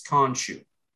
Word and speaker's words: Khonshu: I always thought it was a long O, but my bad, all these years, it's Khonshu.